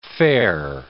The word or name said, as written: fair